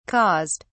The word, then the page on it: caused: British English